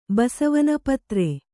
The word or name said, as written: ಪತ್ರೆ